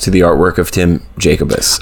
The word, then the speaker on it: Jacobus: His wild and quirky stories of the macabre, conjoined with, like you said earlier, Tim Jacobus.